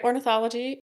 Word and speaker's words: ornithology: Did you like get a special degree to go into ornithology? Did I say that right? – Yep, you said it right. Ornithology.